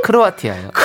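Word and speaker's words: Croatia: 어머, 크로티아나, 크로, 크로, 어디라고? 크로아티, 크로아티아요.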